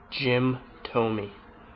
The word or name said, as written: Jim Thome